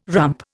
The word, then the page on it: rump: Indian